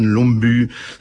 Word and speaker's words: Lumbye: Hans Christian Lomby, ça s'écrit L-U-M-B-Y-E, mais enfin bon, voilà, c'est pas toujours la même prononciation que chez nous, les voyelles, et c'est le rêve après le bal que voici, écrit en 1861.